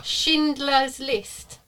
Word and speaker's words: Schindler's List: Schindler's List.